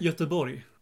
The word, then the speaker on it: Göteborg: How do you say it? – Göteborg. – Göteborg. Oh, so that's like a Y, like Göteborg. – Yeah, that's right.